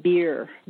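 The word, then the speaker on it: BEIR: And there's always a bad joke because the acronym is pronounced BEER, but it's B-E-I-R, Biological Effects of Ionizing Radiation.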